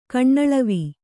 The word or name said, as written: ಕಣ್ಣಳವು